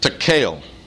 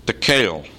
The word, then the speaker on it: tekel: Verse 27, Daniel pronounces the next word, tekel. Tekel. Pronounced tekel, the word is a noun and it means shekel.